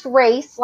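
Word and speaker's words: Racz: Yes, it's pronounced race, like a race car, but it's spelled a little different.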